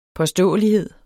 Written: påståelighed

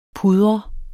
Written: pudre

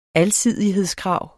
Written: alsidighedskrav

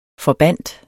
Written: forbandt

